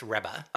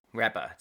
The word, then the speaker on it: Rebbe: I just did a little internet pronunciation thing, and it said it's Rebbe. – Oh, thank you. Rebbe. That sounds way better. Rebbe!